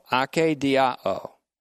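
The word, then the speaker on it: akedia (ἀκηδία): It is ake diao, ake long E-D-I-A-O, ake diao. And it means to be exhausted and to be in anguish. It is related to the noun akedia, that's A-K-E-D-I-A, akedia, which has nuances of meaning that go from indifference and apathy to weariness and exhaustion.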